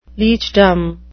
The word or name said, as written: leechdom